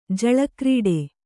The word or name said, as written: ಜಳಕ್ರೀಡೆ